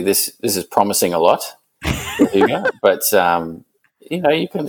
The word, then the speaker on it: Hygge: But the book I borrowed actually has the pronunciation on the front of the cover. It's the book of Hygge, and it has in brackets Hygge.